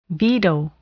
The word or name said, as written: veto